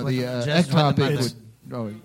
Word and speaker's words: ectopic: So in your sermon on the application in regards to abortion, you did not address ectopic, am I saying it correctly? – Yeah.